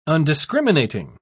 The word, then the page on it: undiscriminating: British English